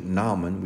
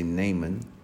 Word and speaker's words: Naaman: It's the double A is not pronounced as a strong A, but it is actually Naaman. We say Naaman.